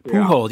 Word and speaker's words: Pujols: In terms of the show's content, you've had on Billy Ray Cyrus, you've had on Albert Pujols, got my pronunciation. – Pujols, yeah.